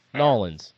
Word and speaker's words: New Orleans: Okay, pronounce it as if it was spelled N-A-W-L-I-N-S. – Nawa Lands. – No, N-A-W-L-I-N-S. Nolans.